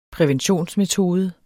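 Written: præventionsmetode

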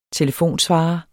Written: telefonsvarer

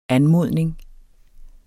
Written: anmodning

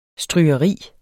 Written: strygeri

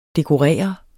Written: dekorere

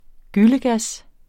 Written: gyllegas

